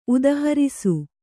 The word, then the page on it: ಉದಾಹರಿಸು: udāharisu